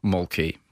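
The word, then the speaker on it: Mölkky: Most of the people we play against use Mulkey, but the Finns have a very unusual pronunciation, which, please forgive me, I'll try. It's Mulke. So I think that's, something a bit more like that.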